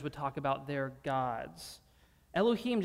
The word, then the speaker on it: Elohim: The word there is Elohim.